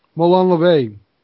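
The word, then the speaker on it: Molon labe: Molon Lob. Molon LeVay, actually, is how it's pronounced. Molon LeVay. LeVay, depending on which version of Latin you speak, the Old Latin or New Latin.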